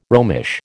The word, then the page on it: Romish: American English